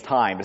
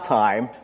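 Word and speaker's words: time (τιμή): The word translated honor there, it's the Greek word time, which confuses me because it's spelled T-I-M-E, which to me is time. It's not time. It's Greek, so it's time.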